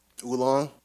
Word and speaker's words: oolong: Oolong? – Yeah. Was it? Is it oolong? I said it wrong. – Oolong, yeah.